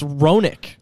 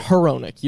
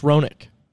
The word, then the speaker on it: Roenick: I also got corrected. It's Roenick. Like Jeremy Roenick. It's really? Not Heronick. You don't pronounce the H. It's Roenick.